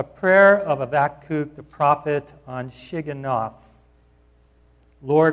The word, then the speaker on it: Habakkuk: His name actually is properly pronounced Habakkuk, for anybody that would like to know that.